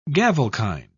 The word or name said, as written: gavelkind